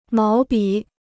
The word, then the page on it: 毛笔: máo bǐ